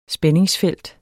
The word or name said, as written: spændingsfelt